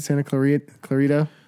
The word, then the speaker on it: Santa Clarita: Santa Clarita? – Yep.